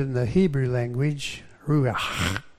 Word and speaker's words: ruach: Now, if you want to spit out the window while you're driving and you're a Hebrew, you could do it well with this word, ruach, because that lasts a little bit. That's how you say it.